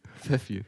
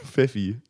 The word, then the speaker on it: Pfeffi: Weil Pfeffi schreibt man ja auch mit, mit ja, F ja, am Anfang, ne? Wichtig. Pfeffi. – Pfeffi. So wie Fuffi, weißt du?